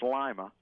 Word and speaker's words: Lima: Actually, our headquarters is in, it's pronounced Lima. – Lima? like a lima bean.